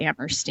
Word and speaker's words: Amherst: Now, do you pronounce the H or not? – I do not pronounce the H. Yeah. I call it Amherst, yeah.